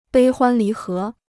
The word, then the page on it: 悲欢离合: bēi huān lí hé